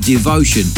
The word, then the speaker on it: DVTN: Shining through there with his latest release on the label that's out on Toolroom Records, and it's called DVTN and pronounced Devotion.